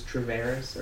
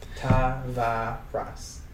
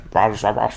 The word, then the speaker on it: Tavares: But the way I pronounce it is how it's spelt for the most part. Tavares. Res. – Right. – Tavares.